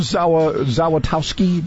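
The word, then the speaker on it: Zawistowski: And yeah, it's actually a simple Polish name because actually all the letters are pronounced. Z-A-W-Zaw-I-S is T-O-W-Tau-S-K-I. Zaw is Towski. It's like Smith in Polish.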